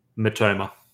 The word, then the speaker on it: Mitoma: No, WAN-BISSAKA was really good, and look, I think is it MITOMA? Is that how you say his name? – MITOMA.